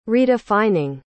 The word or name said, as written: redefining